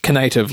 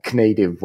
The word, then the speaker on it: Knative: I always thought that Knative should be pronounced Knative, like Donald Knuth. – Yeah, no, there was a lot of discussions about how should it be pronounced, and Knative was definitely there, and it was like, no. But you know, whatever. And there's always Mark who calls it Cantive.